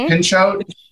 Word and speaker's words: Pinchot: Pinchot.